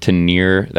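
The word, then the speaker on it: Ténéré: There was a fellow on a Tenere, I believe. That's how you say it, Tenere.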